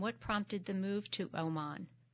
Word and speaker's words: Oman: Is it Oman or – It is Oman. – Oman. – Yeah.